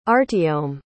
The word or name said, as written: artyom